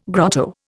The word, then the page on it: grotto: Indian